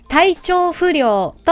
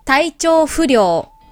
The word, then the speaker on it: 体調不良: 今日のタイトルの漢字、皆さん、読めますかこれは、体調不良と読みます。体調不良、体調不良。